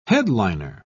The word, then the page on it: headliner: British English